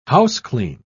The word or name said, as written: houseclean